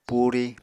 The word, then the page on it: puri: apatani